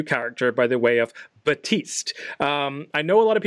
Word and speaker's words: Baptiste: There's no P. You don't pronounce the P. It's Batiste.